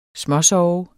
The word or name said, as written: småsove